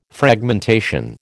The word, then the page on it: Fragmentation: American English